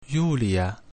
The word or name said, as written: Iulia